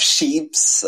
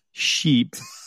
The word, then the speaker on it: ships: You have ships. – Wait. But pronunciation, we have to be careful. Ships and boats. Because if you say sheep, then I didn't take care of the pronunciation.